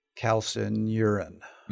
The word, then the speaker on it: calcineurin: But the pathway used that they believe the long endurance work hits is this calcium comodulin calcineurin, C-A-L-C-I-N-E-U-R-I-N.